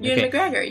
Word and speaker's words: Ewan McGregor: Ewan McGregor.